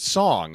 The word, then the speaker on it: Sung: I think Sung with a U. – Yeah, but they pronounce it Song, which I found interesting.